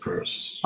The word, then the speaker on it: Peirce: Okay, it, it, not not Pierce. People often yeah. say Pierce, but he pronounced it Pierce.